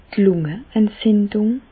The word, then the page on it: d' Lungeentzindùng: Bas Rhin; Reichshoffen